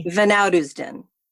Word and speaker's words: van Oudheusden: Van Oudisden.